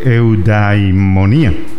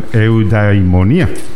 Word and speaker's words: eudaimonía: Platón quería saber cómo una sociedad podía mejorar su producción, pero no precisamente de poder militar, sino de gente llena de eudaimonía. Vuelvo y repito, es difícil la pronunciación, eudaimonía. – Eudaimonía.